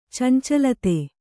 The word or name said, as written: ಚಂಚಲತೆ